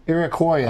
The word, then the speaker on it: Iroquoian: What does the word Canada mean in Iraqian? – I wouldn't. – Is it like hello? – Is it Iroquoian? How do you say it? Iroquoian.